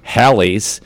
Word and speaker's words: Halley: Until 1952, WPWA radio station program director Bob Johnson suggested Haley, rhymes with Bailey, take advantage of the common mispronunciation of Halley's, rhymes with Sally's Comet.